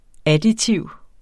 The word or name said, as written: additiv